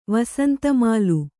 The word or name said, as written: ವಸಂತಮಾಲು